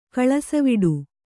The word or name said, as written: ಕಳಸವಿಡು